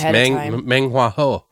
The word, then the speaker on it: Meng Hua Ho: I think you said it right. It's Meng Hua Ho.